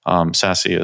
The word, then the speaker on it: SASE: SASE is a Secure Access Service Edge, SASE. I don't know why they pronounced it SASE, but you know, everybody does.